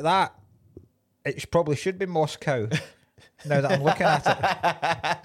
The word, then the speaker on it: Moscow: Another thing that annoys me, and this doesn't just annoy me in this film, it annoys me in every film, how Americans say Moscow. – Moscow. Yeah. – Thanks very much for your hospitality, Moscow. – Yeah. – Fuck off. – Yeah, it's Moscow.